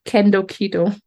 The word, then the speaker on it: Kendo: And so you call it kendo keto. Kendo, like you can do keto, right? And but kendo is spelled K-E-N-D-O.